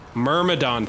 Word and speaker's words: Myrmidon: Myrmidon, thank you.